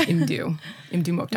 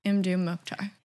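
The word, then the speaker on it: Mdou Moctar: I heard him say his name himself. It is Imdu Mokhtar. – Nice. Okay. Imdu Mokhtar.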